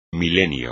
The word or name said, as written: milenio